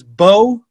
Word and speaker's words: Beaux: And the player's name is Bo Bressler. Bo is spelled B-E-A-U-X. Bo Bressler. B-R-E-S-S-L-E-R. – X? – B-E-A-U-X.